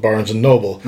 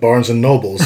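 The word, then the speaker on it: Barnes & Noble: I was at a store called Barnes and Noble, but I accidentally said several times Barnes and Nobles. I've been informed that that's not how you say it.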